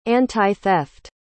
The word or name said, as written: antitheft